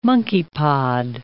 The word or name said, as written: monkeypod